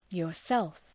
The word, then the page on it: yourself: jɔ:r’self